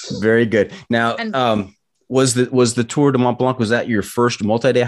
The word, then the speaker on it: Tour de Mont Blanc: I do not do justice to the Tour de Mont Blanc. You pronounce it much better. Say it one more time for us. – Tour de Mont Blanc.